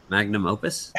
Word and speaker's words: magnum opus: I said that wrong. Magnus copus? What is it? – Magnum opus? – Magnum opus. There it is.